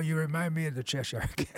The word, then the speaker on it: Cheshire: Um, the next tune is I don't know the, the, pronunciation. Cheshire Cat. Cheshire Cat. Cheshire Cat. You know, like England, Cheshire.